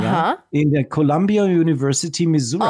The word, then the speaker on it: Missouri: Missouri? Was soll das sein? Oder meinst du Missouri? – Ist die coole Aussprache von Missouri.